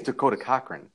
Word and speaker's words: Cochrane: Did you purposely mispronounce his name? – No. – Oh, I think it's Dakota Cochrane. I don't think that he emphasizes the cock in his name, although maybe he does.